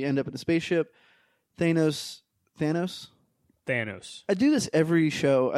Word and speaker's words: Thanos: Say it with me. Thanos. – Thanos. – 1, 2, 3. – Thanos. – You got it. Okay, so the movie opens. They're on Thor's ship. Thanos has kicked everyone's butt.